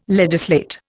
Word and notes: legislate: Indian

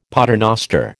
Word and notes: paternoster: American English

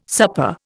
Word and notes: supper: Indian

